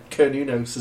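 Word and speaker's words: Cernunnos: Konunos is the password. – Konunos? How do you spell that? – It's spelled C-E-R.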